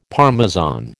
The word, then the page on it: Parmesan: American English